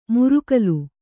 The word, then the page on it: ಮುರುಕಲು: murukalu